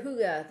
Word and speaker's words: hygge: And then in other areas of Europe, and I think it's the Netherlands or Finland, it's okay. hygge. – That's right, hygge. That's yes. that H-Y-G-G-E that I kept yes.